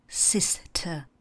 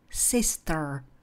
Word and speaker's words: sister: A zase já se kloním k té britské angličtině, k té britské výslovnosti. A kdybyste chtěli americkou, ta by zněla spíše jako Sister. Je tam náznak toho R, které Britové v tomhle případě nevyslovují. Porovnejte. Sister, sister.